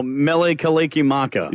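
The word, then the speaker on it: Mele Kalikimaka: Oh, Mele Kalekumala.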